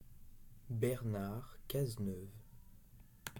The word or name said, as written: Bernard Cazeneuve